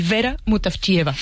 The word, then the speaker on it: Vera Mutavcheva: Ayúdanos a pronunciar, los autores. – A ver, Georgi Gospodinov, ¿sí? – Zdravka Eftimova y Vera Mutavcheva.